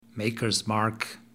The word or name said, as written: Maker's Mark